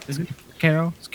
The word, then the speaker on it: Sekiro: So and so Sekiro, am I pronouncing that right? – You are. – Sekiro.